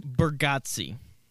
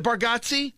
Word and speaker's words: Bargatze: I never know how to pronounce Nate's last name, the comic. Bargatze. Do you know how to pronounce that? – Bargatze. – Is it Bargatze? – Mm-hmm.